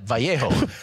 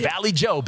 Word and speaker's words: Vallejo: So in Spanish, you would pronounce that Vallejo. But if you're a Bay Area native, you know to call it Vallejo. – Vallejo. Vallejo. Okay. – Vallejo. Yeah. Valley Joe, baby.